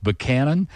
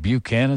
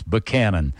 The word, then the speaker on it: Buchanan: It's spelled Buchanan, but it's pronounced Buchanan. And the only reason I knew that is because I had some friends who lived there, and they said, no, it ain't Buchanan, it's Buchanan.